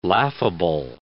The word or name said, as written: laughable